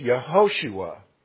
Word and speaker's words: Yehoshua: Now, we pronounce it Joshua, but in the Hebrew, that's a long O in there, so it's Yehoshua.